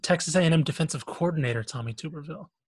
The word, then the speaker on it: Tuberville: It's Tuberville, but... – Tuberville. No, it's Tuberville, sir.